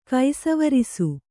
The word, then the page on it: ಕೈಸವರಿಸು: kaisavarisu